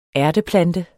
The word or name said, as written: ærteplante